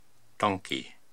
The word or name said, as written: Tonki